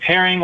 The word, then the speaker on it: Hering: Yeah, and your last name is spelled H-E-R-I-N-G, correct? – Yeah, correct. It's like herring, like the fish, but one R.